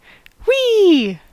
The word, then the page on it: whee: [wi]; /wiː/